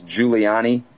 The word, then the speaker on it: Giuliani: Charles Giuliani, and it's spelled the same as Rudy, unfortunately.